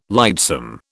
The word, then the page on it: lightsome: British English